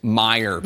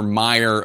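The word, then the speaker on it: Meijer: I need to correct myself. I just got a text from a member of Congress from Michigan. It's Meyer, Congressman Peter Meyer of Michigan. I'm sorry I mispronounced your name on this big day when you were braver than most of your colleagues, Congressman Meyer.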